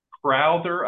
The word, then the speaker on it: Crowther: Well, Wells Crowther, I guess it's pronounced, C-R-O-W-T-H-E-R. He's a former Boston College lacrosse player who,